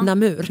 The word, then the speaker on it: Namur: Jag tänker att i mitt huvud uttalas det som lemur. Liksom. – Ja. – Namur.